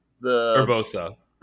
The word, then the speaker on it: Urbosa: So, also honorable mention, who is it Orbusa? Is that the one from the desert? – Urbosa. Yeah.